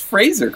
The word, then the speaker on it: Fraser: And this one, it's way to pronounce Fraser correctly.